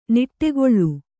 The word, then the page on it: ನುಡಿಗೇಳ್: nuḍigēḷ